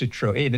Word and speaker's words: Citroën: But, yes, pronounced Citroën. It's a bit like a Moët and Chandon. People go, it's Moët. No, it's not. It has a dilated E, so it's Moët. – Moët. Oh, okay. So you do pronounce the N on the end.